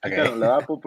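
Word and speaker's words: Kitan Oladapo: Estaba viendo que se pronuncia Kuton Oladapo de Oregon State, que es Kuton Oladapo, pero bien pronunciado.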